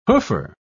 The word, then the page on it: hoofer: British English